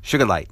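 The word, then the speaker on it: sugilite: In 1944, when this was discovered, this was a Japanese scientist, Kinichiya Sugai, Sugi, and the G, it's not a soft G, it's a hard G like in goose. So it's sugarlite. You got to really pronounce that G, okay? I'm tripping over my tongue. But remember that G is a powerful G. It's not a soft G, okay?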